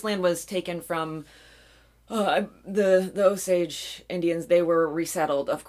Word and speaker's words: Osage: Actually, everywhere I listen to podcasts, they call the county, which is named after the tribe, Osage. – Osage. – I believe, yeah. – That sounds right. That sounds right. So that's the way we're going to pronounce it.